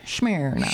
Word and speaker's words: Smyrna: Smyrna.